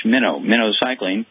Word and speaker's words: minocycline: This is arthritis and osteoporosis, but there's an overlying infection with a bug called mycoplasma, which is killable with an antibiotic called minocycline, pronounced like the bait fish, minocycline.